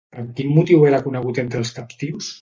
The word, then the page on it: captius: catala